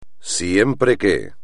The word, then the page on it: siempre que: espagnol